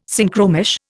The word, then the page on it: Synchromesh: Indian